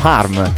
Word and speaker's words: Harm: Vi ricordate Safe From Home? Te la ricordi? – From Harm, forse. Harm. Ti faccio le pulci pure sulla pronuncia.